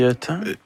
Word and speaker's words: Ceuta: C'est Ceuta. – C'est Ceuta.